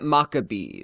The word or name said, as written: Makabi